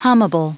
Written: hummable

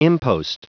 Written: impost

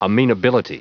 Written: amenability

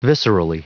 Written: viscerally

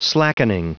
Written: slackening

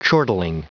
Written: chortling

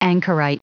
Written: anchorite

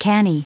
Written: canny

